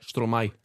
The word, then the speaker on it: Stromae: Stromae.